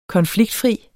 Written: konfliktfri

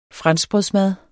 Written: franskbrødsmad

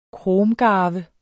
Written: kromgarve

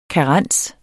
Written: karens

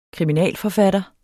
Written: kriminalforfatter

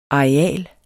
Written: areal

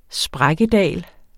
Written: sprækkedal

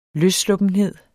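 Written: LØSSLUPPENHED

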